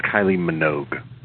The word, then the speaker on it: Kylie Minogue: I think it's Kylie Minogue.